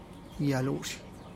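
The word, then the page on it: ya'lush: Teotitlán del Valle Zapotec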